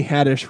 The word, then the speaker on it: Haddish: Also, it's Tiffany Haddish rhymes with radish.